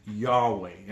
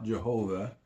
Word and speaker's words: Yahweh: The letters in English are YHWH. And as I said, there are no vowels in the ancient Hebrew text. But consensus among Hebrew scholars, it is pronounced Yahweh, and for instance, not Jehovah, is actually an incorrect pronunciation, and is called by theologians the Tetragrammaton, which really means the four consonants.